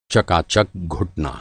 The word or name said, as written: चकाचक